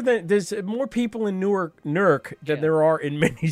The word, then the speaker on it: Newark: They said, take the W out. It's Newark. Like, that's how you say it if you're from there.